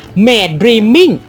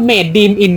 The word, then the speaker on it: Maidreamin: แต่นี้อ่านว่าเมดดรีมิงอ่าเราพูดเมดดีมอินกันตั้ง